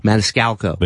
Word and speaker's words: Maniscalco: That was the closest you've come. – What's the matter with me? Maniscalco. – Maniscalco. – There you go.